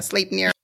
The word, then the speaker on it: Sleipnir: Sleipnir? Did I say that right? – Sleipnir.